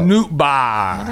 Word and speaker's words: Nootbaar: Is it Nootbaar or Nootbaar? – N-O-O-T-B-A-A-R. – Oh. – Yeah. Nootbaar.